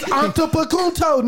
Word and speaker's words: Antetokounmpo: It's Antetokounmpo, nigga. What is it? What is it? Now, Giannis. Antetokounmpo.